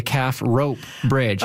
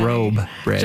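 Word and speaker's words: Calf Robe Bridge: And then the Calf Rope Bridge instead of the Calf funny. – Robe Bridge.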